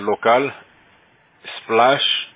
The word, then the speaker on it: Localsplash: Es local, splash, como splash de agua, y después .com.